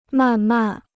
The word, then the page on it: 慢慢: màn màn